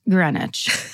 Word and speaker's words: Greenwich: And then in parentheses, it says pronounced Greenwich.